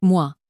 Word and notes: moi: french

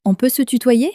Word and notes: on peut se tutoyer: french

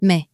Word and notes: mais: French

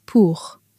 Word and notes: pour: french

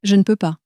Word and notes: je ne peux pas: French

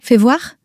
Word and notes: fais voir: French

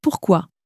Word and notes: pourquoi: french

